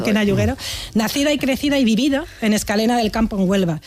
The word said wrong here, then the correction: Escacena: Escacena, mira que te lo he dicho mal varias veces, por esta, Escacena.